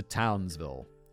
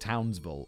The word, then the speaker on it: Townsville: Welcome to Townsville. It's pronounced Townsville, apparently, not Townsville.